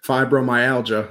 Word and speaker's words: fibromyalgia: How do you say it? – Fibromyalgia, like how it's properly pronounced.